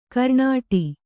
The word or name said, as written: ಕರ್ನಾಟ